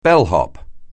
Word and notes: bellhop: anglais

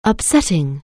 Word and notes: upsetting: anglais

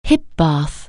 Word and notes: hip bath: anglais

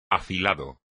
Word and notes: afilado: espagnol

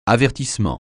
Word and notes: avertissement: francais

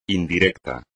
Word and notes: indirecto: espagnol

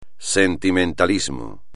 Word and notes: sentimentalismo: espagnol

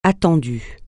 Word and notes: attendu: francais

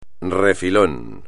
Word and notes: refilón: espagnol

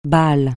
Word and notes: balle: francais

